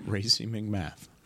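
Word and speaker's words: Racey McMath: Wide receiver Racy McMath. cool name. And running. – How do you spell that? – R A C E Y and M C M A T H. – Racy McMath.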